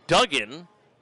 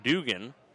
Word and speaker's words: Duggan: And we had the chance to talk about wrestling last night as Colby Duggan, who I initially mispronounced as Dugan, much like Hacksaw Jim Duggan.